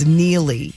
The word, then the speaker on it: Neely: It's Neely. It's his most trusted friend, Neely. The Reacher spinoff. That's how you pronounce it.